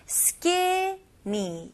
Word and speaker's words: skinny: 여기서는 skinny 이음절입니다. 그래서 skinny가 아니에요. 이거는 외래어고요. 영어로 확실하게 발음하실 거면 skinny, skinny.